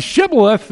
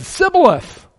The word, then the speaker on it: shibboleth: They couldn't say shibboleth. They said sibboleth. They couldn't get that schwa sound. That's what it is in the original.